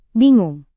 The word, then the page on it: bingung: indonesian